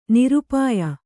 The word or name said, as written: ನಿರಪಾಯ